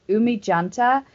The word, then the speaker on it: Oumi Janta: It's Oumi Oumi Janta. So it's yeah, she's in Berlin. My bad. Um, it's O U M I underscore J A N T A, and she's fucking amazing.